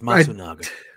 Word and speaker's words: Matsunaga: Kensei Makazawa. I don't want to butcher it. This is brutal. You butchered it already. It's Matsunaga.